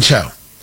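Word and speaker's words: Chao: Is it pronounced Cho? No, it's Elaine Cho.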